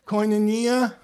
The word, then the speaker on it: koinonia: Koinonia.